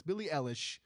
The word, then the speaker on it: Billie Eilish: It's a Billy Eyelash House. And I know I said that wrong. I always call her that. Billy, Billy, it's Billie Eilish.